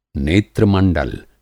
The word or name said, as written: नेत्रमंडल